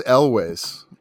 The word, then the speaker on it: Elwes: Like, yeah, they have him for even less time than they have Cary Elwes. How do I pronounce Carrie's last name? – I thought it was Elwes. – Okay, yeah. So think of John Elway.